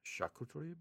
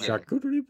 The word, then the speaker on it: charcuterie: So for our appetizer, we got the charcuterie board. – Charcuterie. You don't have to say it like that. You're gonna charcuterie board. – You can say charcuterie.